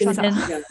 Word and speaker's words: synesthesia: Synesthesia.